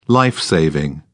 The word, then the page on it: life saving: British English